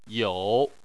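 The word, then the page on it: you: tone 3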